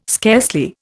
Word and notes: scarcely: Indian

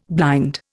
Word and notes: blind: Indian

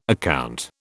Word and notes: account: British English

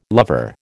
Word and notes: lover: American English

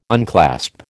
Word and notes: unclasp: American English

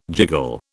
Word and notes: jiggle: British English

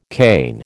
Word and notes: Cain: American English

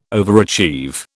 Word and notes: overachieve: British English

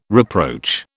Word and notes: reproach: British English

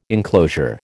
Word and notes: enclosure: American English

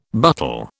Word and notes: buttle: British English

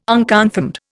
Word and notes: unconfirmed: Indian

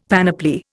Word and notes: panoply: Indian

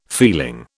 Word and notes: feeling: British English

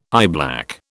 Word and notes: eyeblack: British English

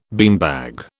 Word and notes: beanbag: British English

